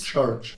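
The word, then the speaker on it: Ćorić: Borna Coric, Francesca Jones. – Chorich. – Oh, I've done it again. Chorich, we were told off about this and I'm still saying it wrong.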